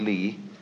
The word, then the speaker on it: li: I'm going to talk to you about another meaning of a word pronounced li later on, but it's quite a different word.